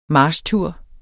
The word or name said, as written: marchtur